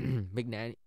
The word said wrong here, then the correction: McAnany: sorry, Mc, McAnany, that's how you pronounce it, said the National Guard and other federal protective services had been deployed.